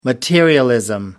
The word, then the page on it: materialism: American English